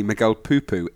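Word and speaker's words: Miguel Pupo: And apparently Miguel Pupu is actually the correct pronunciation of his name. Apparently that's good. How you say it in Brazil.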